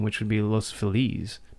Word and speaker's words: Los Feliz: Because that's the correct way to say it. If you've ever taken like a week of Spanish in your life. Los Feliz, it means the happy. Feliz is happy.